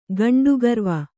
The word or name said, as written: ಘಂಟಿಕಾರವ